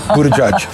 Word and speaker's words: Buttigieg: I'm not trying to avoid trying to pronounce the name. It is Buttigieg.